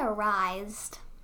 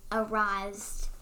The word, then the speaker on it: Erised: How do you say the word E-R-I-S-E-D? – I say arised, but... I mean, is arise even a word?